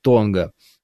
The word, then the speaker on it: Тонга: Значит, желоб, кстати, правильно говорить, Тонга.